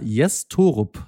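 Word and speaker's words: Jess Thorup: Jes Torup, so wird er tatsächlich ausgesprochen, soll die Fuggerstädter wieder stabilisieren.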